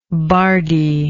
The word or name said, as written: bardee